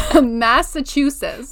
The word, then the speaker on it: Massachusetts: Massachusetts. Massachusetts. – Like Massa, Massa. and then Chew. – Chew. Sis. Like sis. Sis. Chew sis. – Massachusetts. Chew sis. Yeah. That's actually it. how you say it.